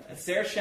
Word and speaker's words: Saoirse: Saoirse, as in inertia, is my personal favorite.